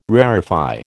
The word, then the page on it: rarefy: American English